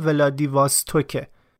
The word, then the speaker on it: ولادیواستوک: اسم شهری که من بارها توی دو تا اپیزود قبلی آوردم ولادیواستوکه و من اشتباه تلفظش کردم و عذرخواهی میکنم.